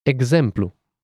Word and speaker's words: exemplu: First, we have ks. Ks and the example xenophobe. – Xenophobe. – Gz. Gz. Exemplu.